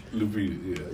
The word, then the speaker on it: Lupita: Then you got Lapita out there Who's who's man? What's wrong? Did I say it wrong? Lupita, yeah. Yeah, her, yeah, her man dead.